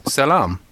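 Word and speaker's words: salam (سلام): Well, I did a little bit more digging, and it turns out the way you pronounce the word peace in Arabic is salam.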